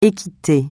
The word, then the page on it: équité: français